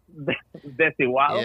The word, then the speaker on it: Desigual: This is called Desidual? Desidual? – Yeah. – Oh, dude. – That's a wow. – Oh, wait, say it again. How do you say it in your language? – Desigual. – Yeah.